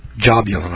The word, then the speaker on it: Jahbuhlun: If I can remember how to pronounce this one, it's spelled J-A-H-B-U-H-L-U-N. Jabulun, I believe it is.